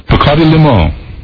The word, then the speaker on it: Bacardi Limon: When you announce Bacardi Limon, here's how we'd like you to pronounce it. – Bacardi Limon.